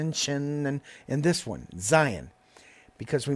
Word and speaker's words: Zayin: We're ready to talk about the seventh letter of the Hebrew alphabet, which is pronounced Zayin. Zayin. Z-A-Y-I-N. You can see that in your chart as well. Zayin is, once again, a two-syllable word. So we put the emphasis on the first syllable to get us to sound out the Z sound.